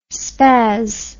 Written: spas

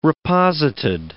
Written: reposited

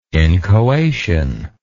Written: inchoation

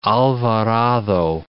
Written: Alvarado, de

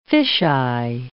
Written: fish-eye

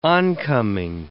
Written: oncoming

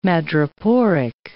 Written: madreporic

